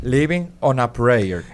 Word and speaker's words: Living on a Prayer: ¿Cómo pronunciarlo ahí, por favor? – Living on a prayer.